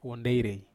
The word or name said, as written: huonderei